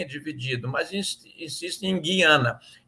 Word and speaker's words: Guiana: Agora, no caso de Guiana, nós temos um ditongo que deve ser pronunciado.